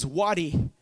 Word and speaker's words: wadi: But the pronunciation, best I could tell, is Wadi, W-A-D-I.